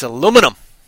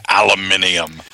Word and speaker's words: aluminum: And then we told him that it's like, no, that's not the way you spell that. It's, or pronounce that, rather. It's aluminum. – Yeah, aluminium.